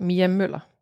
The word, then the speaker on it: Müller: bit more like Mia Müller.